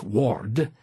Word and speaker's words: word: The very word word in Old English, pronounced ward, doesn't mean what you and I think of as a word, something in the dictionary.